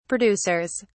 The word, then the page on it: producers: British English